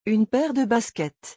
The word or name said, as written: une paire de baskets